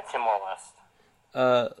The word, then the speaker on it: Timor-Leste: 33, Timor-Leste, I think that's how you say it. – Yeah, Timor-Leste.